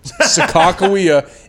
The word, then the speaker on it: Sacagawea: Sacagawea is how I was told that Sacagawea's name is actually pronounced.